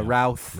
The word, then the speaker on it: Routh: Roth. – Yeah. – Routh. – Routh. – Yeah. It's pronounced like yeah. South, and it's Bryan Singer.